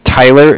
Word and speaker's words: Tyler: My name is Tyler. It's T-Y-L-E-R.